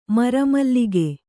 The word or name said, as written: ಮುರಿಮಲಕು